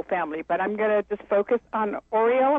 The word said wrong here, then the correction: Oria: Okay, yeah, the mother is Orit and the daughter is Oria.